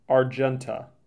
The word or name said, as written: Argenta